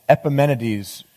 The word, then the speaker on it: Epimenides: And Epimenides rhymes with EpiPen for bees, which I think is just the coolest thing ever.